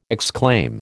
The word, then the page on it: exclaim: American English